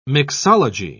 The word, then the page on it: mixology: British English